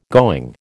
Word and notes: going: American English

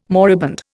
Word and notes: moribund: Indian

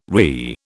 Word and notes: RI: British English

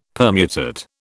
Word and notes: permutate: British English

